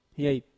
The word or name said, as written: hiait